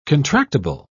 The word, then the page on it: contractible: British English